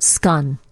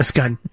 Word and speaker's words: scone: She also, when she went to, there's a video of her, too, greeting a British baker who gave her a scone, but she pronounced it scun. – A scun.